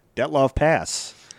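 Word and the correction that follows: Dyatlov Pass: Dietlov Pass. – Dietlov Pass. Yeah, because didn't somebody say I was always saying it wrong? – Well, no.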